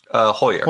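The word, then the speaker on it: Heuer: And Richard, I would say it probably Hauer. How did you pronounce his name? – Hoyer. – Hoyer. Well, I guess I got to ask you, working for the CIA must have been pretty cool at times.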